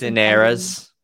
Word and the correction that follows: quinceañeras: Oh, quinceañeras.